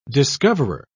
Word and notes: discoverer: British English

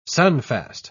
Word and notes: sunfast: British English

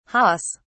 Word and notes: hahs: British English

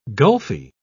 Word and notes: gulfy: British English

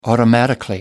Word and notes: automatically: American English

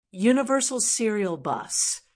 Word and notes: universal serial bus: American English

